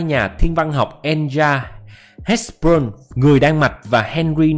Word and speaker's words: Hertzsprung: Đó, đây là biểu đồ đọc là Hertzsprung ha các bạn.